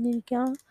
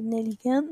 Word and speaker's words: Nelligan: Donc, en principe, on devrait dire Émile Nelligan, Nelligan, mais lui, il insistait pour faire prononcer son nom à la française.